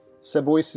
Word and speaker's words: Seboeis: Sebois is spelled S-E-B-O-E-I-S.